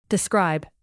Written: describe

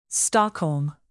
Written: Stockholm

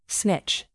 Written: snitch

